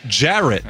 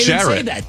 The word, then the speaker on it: Jarrett: Okay, Jarrett. – Then say that. – I do. – No, say it with a qualifier. If you pronounce Jarrett the way I do, it doesn't rhyme with ferret.